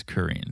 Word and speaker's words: Karin: It's spelled Karin, but it's K-A-R-I-N. – Uh-huh. – It's Karin. It's pronounced Karin, I believe.